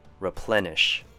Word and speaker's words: replenish: Few sounds like the word F-E-W, few, and sli, profusely. Next word, replenish. 3 syllables. R has the short U sound, ple has the short E sound, and nish. I know there's an I here, but try saying it with a short U sound instead. Replenish.